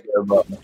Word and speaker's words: Siriboe: I want to say Siriboe. Did I get it right? – Siriboe.